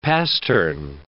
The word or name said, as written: pastern